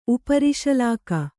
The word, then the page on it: ಉಪರಿಶಲಾಕೆ: upari ślāke